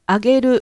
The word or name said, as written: ageru